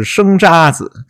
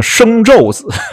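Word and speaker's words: 生查子: 哎，对我们这位著名节目主持人在元宵晚会上就出了一个播出错误啊，明明是生渣子，结果念成了生昼子。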